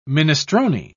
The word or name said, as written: minestrone